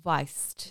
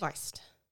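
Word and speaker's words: Weisz: On with the V-type pronunciation of the W rather than the W. – W. – Rachel? – Weist. – Weist.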